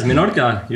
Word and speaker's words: Menorca: How do you say that? – Yes, Menorca. You're good.